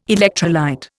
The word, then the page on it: electrolyte: Indian